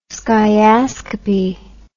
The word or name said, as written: skiascopy